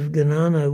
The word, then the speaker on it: Jnana: Gnana, of course, is the Sanskrit form, J-N-A-N-A, of the word which in Hindi and Punjabi is pronounced Gyan.